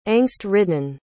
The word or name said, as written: angst-ridden